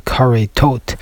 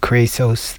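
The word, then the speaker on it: Karisos (כריתות): Of course, there is a discussion about what is the proper way to pronounce this name, and probably the more accurate academic way to call it Karitot, but we will go with the standard way in which it's pronounced in most yeshivas as Karisos, which refers to the punishments that a person gets if a person violates certain sins from the Torah.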